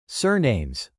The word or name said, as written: surnames